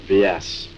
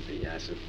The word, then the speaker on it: Vyasa: The monk Vyas, or Vyasa, some people pronounce it Vyasa.